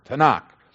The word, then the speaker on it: Tanakh: Well, the Jews have this word Tanakh, and it stands for T-N-K. And they put the A's in there, T-A-N-A-K. They put the A's in there so we could pronounce it, Tanakh.